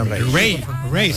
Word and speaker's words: Raye: Ray. Se escribe Raye.